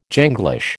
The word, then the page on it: Janglish: American English